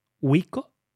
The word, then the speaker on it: Wiko: Y yo lo pienso, no será Wiko, será Wiko. ¿No? – Bueno, sí, pero se escriben exactamente igual, ¿vale? Claro. W-I-K-O, ¿vale? Exactamente igual.